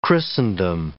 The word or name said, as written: Christendom